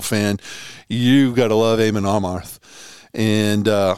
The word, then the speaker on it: Amon Amarth: They're touring with a band with a, with a great band called Amon Armarth. I God, I'm saying that wrong. It's Amon Amarth, um, and former Arizona State head coach Tate Green busted my balls one day.